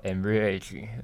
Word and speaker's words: Ambrage: e 就是一个很棒的品牌嘛，而且好像没有什么人注意是 a m b r a g e 之前还跟上季也还跟 Fendi 做联名而已啊，做合作，这也代表它的创新哦被国际品牌给看到嘛。呃，如果不清楚的朋友们可以上网去搜寻哦 a m b r a g e 那日文他们念作 Ambrage。